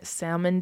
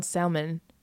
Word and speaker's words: salmon: I can make a mean salmon dish. Salmon? Salmon? Why don't we pronounce the L in salmon? Salmon? S-A-M-O-N. Salmon.